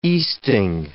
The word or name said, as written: easting